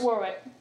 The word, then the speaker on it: Warwick: How do you say that? – That's Warwick. Yeah.